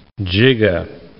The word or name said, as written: giga-